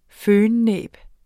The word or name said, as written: fønnæb